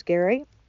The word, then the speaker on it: Gerry: The reason I really want to check it out is because Gary, G-E-R-R-Y, but pronounced Gary, I always have to remind people, makes a banana pudding from the Magnolia.